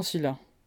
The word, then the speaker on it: Scylla: Charybde se prononce Charybde et Scylla, Scylla.